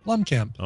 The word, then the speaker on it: Blomkamp: Blomkamp.